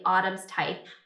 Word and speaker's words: Autumn's Tithe: And I just finished her first book in the duology, Autumn's Tithe.